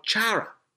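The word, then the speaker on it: Chara (χαρά): The word joy is actually derived from a Greek word called chara or chara. C-H-A-R-A. Chara. That's how it's pronounced in Greek.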